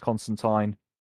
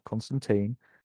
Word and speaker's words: Constantine: You know, Jenna Coleman as Constantine, rhymes with wine. It's not Constantine.